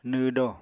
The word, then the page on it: nw do: Apatani